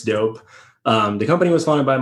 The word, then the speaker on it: Doughp: First of all, I really like your company name, Doughp, which is like pronounced like that's dope, but it's spelled the way it is, D-O-U-G-H-P.